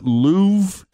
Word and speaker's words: Louvre: They even released a video for a track that was filmed inside the Louvre in Paris. Did I pronounce that right, Fritchie? – You did a good job.